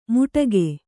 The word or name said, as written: ಮುಟಗೆ